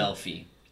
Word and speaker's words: Melfi: Melfi. – Let's that's go what with I said. Melfi.